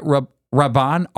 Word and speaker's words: Rabban: Rabban, R-A-B-B-A-N? – Yeah.